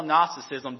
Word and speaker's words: Gnosticism: Now, if you need to spell Gnosticism, do not spell it with an N, spell it with a G, okay? It's G-N. Now, if you want to remember how to spell it, just remember, Gnosticism is annoying like a gnat, okay? Because you spell gnat with a G and not an N if you didn't know that either, okay?